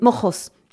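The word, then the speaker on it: Moxos: Ah, je dis l'ensemble Moxo, mais oui, je me trompe. Donc, c'est avec un X, mais en espagnol, on peut le prononcer avec comme un G, comme Jérôme. Mais c'est Mojos.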